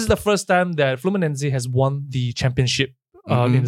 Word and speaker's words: Fluminense: It is pronounced as Fluminense. It sounds like Flu by Nancy, but never mind, I try my best, yeah?